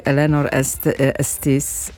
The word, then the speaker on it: Eleanor Estes: Akurat ta książki Eleanor Estes, tak to się wymawia, 100 sukienek jest o tyle fascynująca, że jest to książka, która opowiada o polskiej dziewczynce.